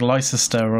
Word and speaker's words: Leicester: It's like it's like Leicester or something like that, yeah, but it's pronounced Leicester.